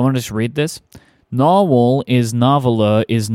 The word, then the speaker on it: Nahvalur: When I asked Frank, he said it's pronounced Narwhal. So basically, the brand's name went from Narwhal to Narvaler, but stayed Narwhal. So I suppose I'll stop using random pronunciations in videos. So the way you pronounce that word is Narwhal.